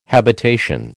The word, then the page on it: habitation: American English